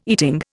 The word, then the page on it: eating: Indian